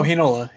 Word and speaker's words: Heinola: Helanen? Oh, Heinola.